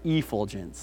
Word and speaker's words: effulgence: This word is infulgence, Okay, you can write that. Did I say that right? Effulgence, not infulgence, sorry, effulgence.